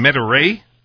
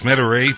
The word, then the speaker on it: Metairie: And from New Orleans, $2.32 in Metairie. I think that's how it's pronounced, Metairie, $2.32 from Manny.